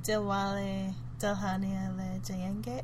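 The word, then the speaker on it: Dilwale Dulhania Le Jayenge: Which was Dilwale... Dilhania Le Jayenge. Did I say it right? Yeah. Yes, you did.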